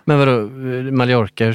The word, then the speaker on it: Mallorca: För de uttalar det så som det ska uttalas, fast med, på väldigt svenska. Med ett, alltså dubbel L då får det ju vara. Mallorca.